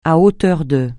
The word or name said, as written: à hauteur de